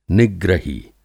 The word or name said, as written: निग्रही